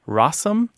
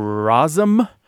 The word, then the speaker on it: rosin: The reason I said we'll talk about rosin in a second is some folks say rossum or rosum or rosing. So, and you'll find all of these pronunciations throughout the American South and Midlands and that sort of thing.